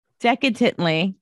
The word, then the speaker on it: decadently: He's sweet and delectantly. How do you say that word? Why have I never known how to say that word? – He's sweet and decadently.